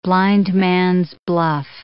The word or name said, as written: blindman's buff